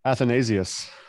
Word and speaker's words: Athanasius: Athanasius. That's the way all the British patristic scholars do it.